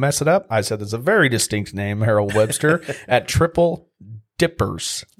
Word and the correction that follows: Triple Dipple's: Dipples. – Dipples. I didn't put an L. – It rhymes. Triple Dipples.